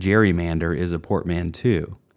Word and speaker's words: gerrymander: Somewhere along the way, we changed the hard G to a soft G and started using the term gerrymander